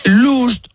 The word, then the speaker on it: lost: Porque vamos a ver, last, L-A-S-T, es... – último. – Lost, L-O-S-T, es... – perdido. – Y lost... no, no, no se pronuncia lust, sino que se dice también lost. Last, L-U-S-T, significa...